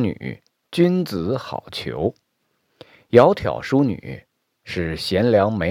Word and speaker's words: 窈窕: 这两个字呢，都是三声，它们组词以后，第一个三声就读如阳平。也就是读如二声，就读作窈窕。